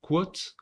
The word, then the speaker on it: Kvothe: Il s'appelle, alors ça se prononce, je crois, quote, comme, un, comme citation en anglais. Ça s'écrit K-V-O-T-H-E.